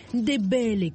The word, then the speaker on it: Ndebele: Ndebele, comme elle le prononce.